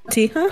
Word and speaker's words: cotija: It is smoked bacon wrapped hot dog, caramelized onions, chipotle aioli, pickled jalapenos, and I'm gonna butcher this name, the it's cheese that's spelled C-O-T-I-J-A. Help me. – Tija? – I'm white. – It's cotija. – Yeah, cotija.